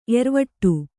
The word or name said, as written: ಏರುವಡೆ